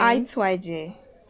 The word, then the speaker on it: Aituaje: Can you pronounce your name, like everything? – Aituaje.